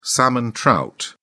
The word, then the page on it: salmon trout: British English